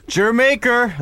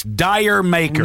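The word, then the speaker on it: D'yer Mak'er: Jermaker. That's how you say it. – It is not. – Yes. – Then why spell it D-Y-E-O? It's Dyer Maker.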